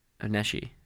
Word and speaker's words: Onishi: But first, we'd like to direct your attention once again to the graphic novel Mr. Guy, Zombie Hunter, from our friends at Onishi Press. Oneshi. Damn it, I said it wrong.